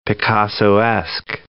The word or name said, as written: Picassoesque